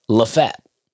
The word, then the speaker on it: Lafayette: That's P-E-N-T-O-N, pronounced Pennon. And then Lafette, which is L-A-F-A-Y-E-T-T-E, spelled like Lafayette. – The French general. – Yes, is pronounced Lafette.